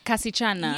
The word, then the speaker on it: Kasichana: Kaschana, like a small girl. – Yes. But you had your eye silent. – Oh, Kasichana.